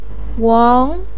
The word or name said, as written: wang2